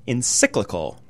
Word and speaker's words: encyclical: encyclical.